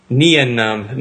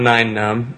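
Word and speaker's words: Nien Nunb: And then later on in the message, it's definitely Nien Num, not Nine Num.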